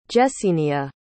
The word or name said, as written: Jesenia